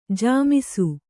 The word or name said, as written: ಝೊಮ್ಮಿಸು